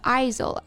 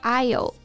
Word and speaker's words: aisle: 对，那 aisle 这个单词呢，A I S L E，aisle 其中的 s 也是不发音的，不要读成了 aisle，而是 aisle。